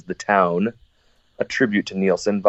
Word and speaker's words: Nielsen: Yeah, it's actually a cover that we did with Cheap Trick of a Harry Nielsen song, which, not to be confused with Rick Nielsen or Daxx Nielsen, but same pronunciation, different spelling.